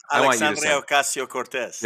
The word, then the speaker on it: Alexandria Ocasio-Cortez: Alexandria Ocasio-Cortez.